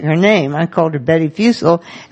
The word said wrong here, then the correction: Fussell: and in fact, it is Fussell rhyming with Russell.